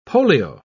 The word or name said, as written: polio